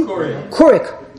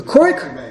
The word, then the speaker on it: Keurig: Am I pronouncing that correctly? – Keurig. – The Keurig? – Keurig.